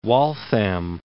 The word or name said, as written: Waltham